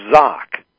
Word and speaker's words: Zouk: His name was Zok. Z-O-K. But it's spelled Z-O-U-K. Zok.